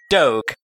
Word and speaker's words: Doge: usually pronounce it like Doge. Because... the G in dog would never be zh.